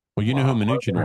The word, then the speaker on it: Mnuchin: How do you say that name? Mnuchin? – Mnuchin. – Mnuchin. Okay, Mnuchin.